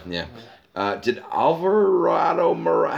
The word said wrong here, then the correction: Alvaro Morata: Alvaro Morata.